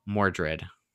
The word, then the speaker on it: Mordred: And so, with Merlin's help, aka Miles, we have Allie successfully, aka King slash Queen Arthur, defeating the evil Mordred.